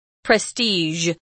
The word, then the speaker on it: prestige: Do you say prestige or prestige? – I do, but I was going to say that, you know, that's a hard consonant for Chinese people to pronounce. – With the zh.